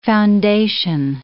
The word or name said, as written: foundation